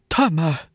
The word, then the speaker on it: Temu: Vad heter det? Temu. – Ja, du vet den här kinesiska appen. Tydligen är det så man uttalar det.